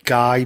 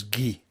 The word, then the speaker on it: Guy: I know his name looks like Guy, but it's actually, the Flemish pronunciation is Guy.